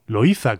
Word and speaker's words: Loíza: Bien, pues a pocos kilómetros de la capital de San Juan de Puerto Rico se encuentra la localidad de Loíza, Loíza como pronunciaríamos en la mayor parte de la península ibérica, que es un feudo de cultura y tradición afro-puertorriqueña, afro-caribeña.